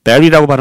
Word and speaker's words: bury: bury라고 발음합니다. 발음 주의하시고요. B U R Y bury buried treasure 묻혀 있는 보물 treasure 그죠?